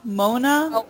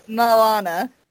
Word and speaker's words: Moana: How do I pronounce Moana? Mona? – Moana. – Moana. – A-N-A.